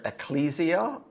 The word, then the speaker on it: ecclesia: Some folks pronounce that word ecclesia. I think either one is a fair pronunciation.